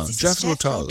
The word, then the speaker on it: Jethro Tull: Jethro Tull? Tull? Tull? – Ha. Ach so, Jethro Tull. Ja, Jethro Tull.